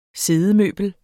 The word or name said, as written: siddemøbel